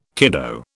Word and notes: kiddo: British English

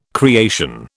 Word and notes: creation: British English

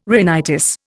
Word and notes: rhinitis: Indian

